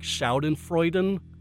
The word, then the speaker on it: Schadenfreude: The one word is like Schadenfreude. Something like that. Did I pronounce that right? – Mm-hmm.